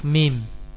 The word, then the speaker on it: mim: Karena orang yang karena mim, huruf mim, ya, cara pengucapannya kedua bibir dikatupkan. Nah, kedua bibir dipertemukan, ya, dikumpulkan. Mim.